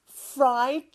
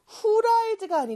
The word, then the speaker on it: fried: 후라이드가 아니고 fried예요. 근데 F하고 R 둘다 우리말에는 없는 발음이라 조금 어려울 수 있지만 저와 함께 연습해 볼게요.